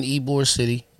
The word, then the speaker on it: Ybor City: I was in Ybor City, walking through. It's spelled Ybor, but it's pronounced Ybor City.